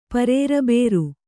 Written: ಪರೇರಬೇರು

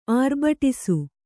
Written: ಆರ್ಭಡಿಸು

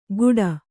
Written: ಘೂಟ

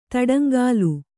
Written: ತಡಂಗಾಲು

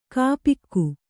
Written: ಗೋಪಿಕೆ

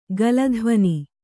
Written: ಗಲಧ್ವನಿ

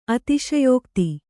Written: ಅತಿಶಯೋಕ್ತಿ

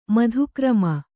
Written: ಮಧುಕ್ರಮ

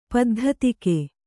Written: ಪದ್ಧತಿಕೆ

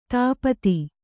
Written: ಧೂಪಿತ

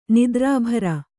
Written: ನಿದ್ರಾಭಾರ

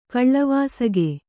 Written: ಕಳವಸಿಗ

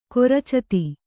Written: ಕೊರಚತಿ

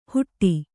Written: ಹುಟ್ಟಿ

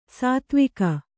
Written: ಸೇದೆವುಗು

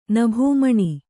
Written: ನಭೋಮಣಿ

